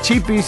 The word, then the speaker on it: WPY: Acordate que Chipi se escribe WPY, chipi.radio.